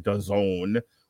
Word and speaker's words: DAZN: But if you're also wondering what's going on in the world of boxing, we have Kiko Martinez versus Josh Warrington on DAZN, which rhymes with calzone.